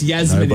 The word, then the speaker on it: Yasmin: Well, oh, it's Yasmin. – Nobody cares. – It's a soft J.